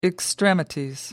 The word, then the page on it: extremities: British English